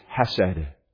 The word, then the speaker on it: hesed: That Hebrew word, to pronounce it most easily, is hesed. H-E-S-E-D.